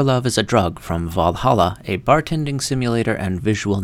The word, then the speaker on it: VA-11 Hall-A: The game's title is stylized as VA-11 Hall-A, although it's pronounced Valhalla like the Viking heaven.